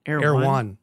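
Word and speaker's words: Erewhon: Is that how you say it? Air One? – Air One, yeah.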